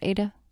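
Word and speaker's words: Adah: is where Ada Price, did you say her name like that, Ada? – Mm-hmm.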